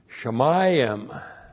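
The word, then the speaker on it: Shamayim (שָׁמַיִם): Shamayim. Looks like this in the Hebrew. S-H-A-M-A-Y-I-M. You see that final I am right here? That's the long I am.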